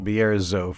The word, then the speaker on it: Bierzo: I can't help but say it correctly and. It's Bierzo. – Bierzo for the rest of us.